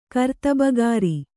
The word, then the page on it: ಕರ್ತಬುಗಾರಿ: Kannada; kartabugāri